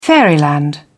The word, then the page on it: fairyland: British English